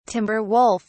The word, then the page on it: timber wolf: British English